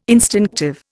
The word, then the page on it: instinctive: Indian